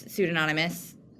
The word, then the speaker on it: pseudonymous: It's pseudonymous. Am I saying that right? Pseudonymous? – You are.